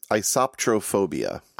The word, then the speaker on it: isoptrophobia: How do you pronounce it again? – I pronounce it isoptrophobia.